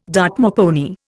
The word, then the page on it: Dartmoor pony: Indian